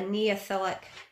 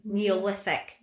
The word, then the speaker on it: Neolithic: And this is backed up by a Neolithic, I've said that wrong again, haven't I? Neolithic.